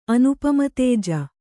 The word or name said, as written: ಅನುಪಮತೇಜ